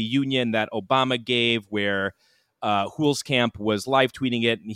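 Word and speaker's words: Huelskamp: I'm probably going to mispronounce his last name, Tim Hulskamp. Is that correct pronunciation there? – Very, very close. Tim Hulskamp. – Hulskamp. Okay.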